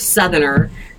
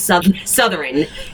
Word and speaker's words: Southerner: And so I was probably in my 30s before I realized the word Southerner was not pronounced Southern. It's pronounced Southern.